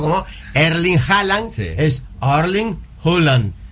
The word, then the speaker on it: Erling Haaland: Para nosotros hasta ahora es Erling Haaland. – Sí. – A ver cómo se pronuncia. – A ver. – Erling Haaland. – Erling Haaland. Ahí está.